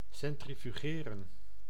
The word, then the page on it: centrifugeren: /sɛn.tri.fy.ˈɣeː.rə(n)/; /sɛn.tri.fy.ˈʒeː.rə(n)/